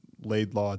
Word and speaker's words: Laidlaw: I mean, it's like very simple phonically. It's Laidlaw, it's spelled Laidlaw. – Right. – My dad always says it, you know, it looks like it sounds, and people still, for whatever reason.